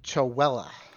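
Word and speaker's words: Chewelah: You all were close, but it's Chowella.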